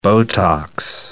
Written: Botox

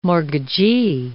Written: mortgagee

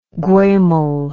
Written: guemal

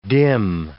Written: dim

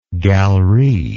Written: galerie

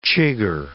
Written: chigger

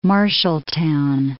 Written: Marshalltown

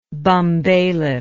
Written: bumbailiff

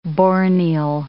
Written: borneol

